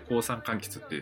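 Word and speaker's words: 香酸柑橘: で、まあ、高山柑橘ってこうどういう柑橘っていうと、香りと酸の柑橘って書いて高山柑橘って読むんですけど、まあ、それが、なんでしょう、みかんとかああいうこう食べて美味しいというよりは、ライムとかレモンみたいに絞ったりとかしてこう香りとか酸味とかを楽しむ系の、そういった、柑橘類を植える予定です、はい。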